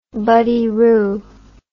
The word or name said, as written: buddyroo